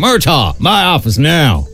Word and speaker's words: Murtaugh: Murtaugh. That's how you pronounce it in America.